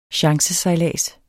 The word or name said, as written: chancesejlads